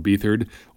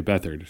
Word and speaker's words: Beathard: I really want to call him Beathard or Beathard, but his name is C.J. Beathard, so we will carry on with the correct pronunciation.